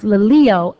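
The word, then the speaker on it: laleo (λαλέω): One is pronounced laleo, L-A-L-E-O, and the other is pronounced lego, L-E-G-O. In our text here, the Greek verb for speak is laleo, L-A-L-E-O.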